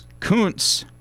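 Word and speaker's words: Kunce: And then you have Lucas Kuntz. And I did the research. That is how you pronounce that. I wanted to make absolutely sure. That's K-U-N-C-E.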